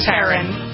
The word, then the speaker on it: Taron: Taron, is that how you pronounce it? Egerton? – Taron.